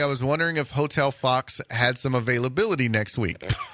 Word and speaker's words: Fuchs: And she, their last name is Fox, but it's spelled F-U-C-H-S. – It's not Fuchs? – Nope. It's pronounced Fox. F-U-C-H-S.